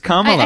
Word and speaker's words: Kamala: It's Kamala. I, I said it wrong.